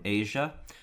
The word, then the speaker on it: Aja: Next one I've got is by Steely Dan, and it is the album Asia. And that's spelled AJA.